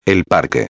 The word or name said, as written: el parque